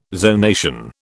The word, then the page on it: zonation: British English